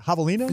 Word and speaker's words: javelina: Is that a javelina? – Javelina. – Like that? – Javelina? – Is it a javelina? I didn't know how to say it. Yeah. Javelina.